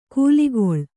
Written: ಕಲ್ಲಿಗೂಳು

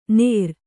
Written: ನೇರ

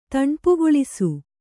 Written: ತಣ್ಪುಗೊಳಿಸು

